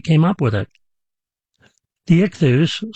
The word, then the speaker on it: ichthus: In Greek, the word for fish is spelled I-C-H-T-H-U-S, I-C-H-T-H-U-S.